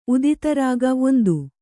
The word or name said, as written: ಉದಿತರಾಗ